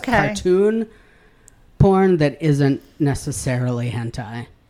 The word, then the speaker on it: hentai: Hentai which in the pronunciation does also have the long vowel over I, so it is indeed hentai, is a genre of Japanese manga and anime characterized by overtly sexualized characters and sexually explicit plots and images.